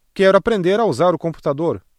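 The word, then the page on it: computador: Brazilian Portuguese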